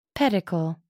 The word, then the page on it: pedicle: American English